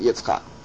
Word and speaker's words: Yitzchak: And instead it's called Yitzchak because the Yud of Sarah's name moved to the front of Tzchak to pronounce it Yitzchak.